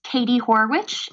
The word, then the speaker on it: Katie Horwitch: You can find me on Instagram at Katie Horwitch. It's my entire name. It's WITCH. Some people leave out the T. It's spelled like witch.